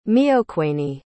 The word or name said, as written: meoquanee